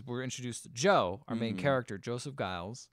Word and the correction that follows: Gillis: Gillis, you're right. Yeah. I said it wrong.